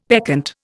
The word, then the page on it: peccant: Indian